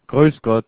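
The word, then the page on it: Grüß Gott: Southern Germany, Switzerland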